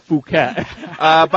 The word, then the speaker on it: Phuket: It's Phuket. Actually pronounced Phuket.